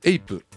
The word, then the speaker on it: HAPE: 株式会社エイプ、これが面白いんですね。 HAPE と書いてエイプの倉野幸次郎社長をお迎えして、送りたいと思います。